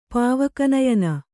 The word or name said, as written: ಪಾವಕನಯನ